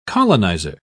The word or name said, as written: colonizer